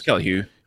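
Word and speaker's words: Kelly Hu: Kelly Hugh, I think that's how you pronounce her name. If not, I apologize. – Yeah, it's Kelly Hugh.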